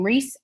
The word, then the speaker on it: Reese: I was her first born, A-L-E-C-H-I-A, last name Reese, R-E-E-S-E.